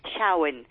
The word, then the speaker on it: Samhain: Y era un festival pagano que los celtas celebraban con el nombre de Samhain, que se pronuncia Samhain, y que es una antigua palabra gaélica que significa el final de la cosecha.